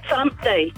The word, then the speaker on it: Thumpty: Thumpty. – Thumpty, like the bunny, like Numpty. – Thumper, like, and rhymes with Numpty.